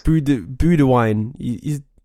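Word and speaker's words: Boudewijn: Budewine. His name's spelled like Budewin.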